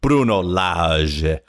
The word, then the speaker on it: Bruno Lage: I have a Portuguese friend who took me through the proper pronunciation, Bruno Lage.